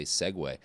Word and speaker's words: segue: Oh, speaking of apprenticeships, that's not a good way to Sieg into an ad. Also, Sieg isn't the right way to say segue.